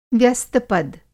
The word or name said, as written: व्यस्तपद